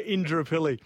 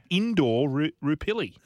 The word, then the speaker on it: Indooroopilly: And so Indooroopilly. Is that how I'm saying it right? – Yeah, Indooroopilly. – Phonetically, it says Indooroopilly.